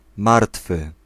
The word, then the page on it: deceased: /dɪ.ˈsiːst/